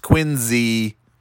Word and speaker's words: Quincy: Anyway, and Quincy, even though there's no Z, it's pronounced Quincy.